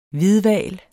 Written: hvidhval

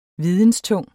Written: videnstung

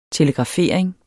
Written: telegrafering

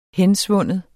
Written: hensvinde

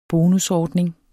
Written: bonusordning

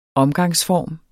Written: omgangsform

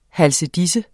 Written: halsedise

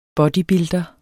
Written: bodybuilder

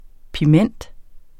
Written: piment

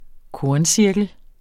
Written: korncirkel

